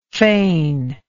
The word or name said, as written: feigningly